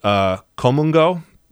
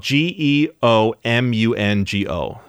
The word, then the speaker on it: geomungo: It's called komungo, which again, it's not spelled that way. – Okay. – So let's see. It's G E. This is a test. You're testing me, Emily. – I am. – It's spelled G E O M U N G O. So look, you know, in English, in our sort of phonetic language, it would sound like geomungo, but it's called komungo.